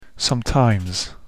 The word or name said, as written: sometimes